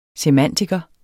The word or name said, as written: semantiker